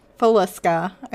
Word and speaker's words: Villisca: it's spelled V-I-L-L-I-S-C-A. So I think it's Villisca Axe Murders. – Villisca, I think.